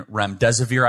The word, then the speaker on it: remdesivir: Yes, and so I want to make sure everyone knows the difference between Regeneron and remdesivir. I always mispronounce it. I think you pronounced it right.